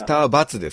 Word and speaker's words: ドクターX: 言ってそれやったこれドクターバツって読むんですかドクターバツです。